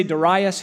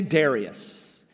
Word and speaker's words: Darius: So you've heard me say Darius here, but it's also acceptable to say Darius.